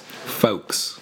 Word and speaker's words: folks: Folks. – How is it folks? There's an L. – You hear it in the K. In the O. Folk.